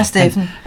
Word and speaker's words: Steven: Det er Steffen. – Han staves Steven, men udtales hans navn.